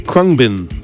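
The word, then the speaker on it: Khruangbin: That band is pronounced Krungbin. Has a few extra letters and things in there. Made it look harder to say than it actually was. Krungbin.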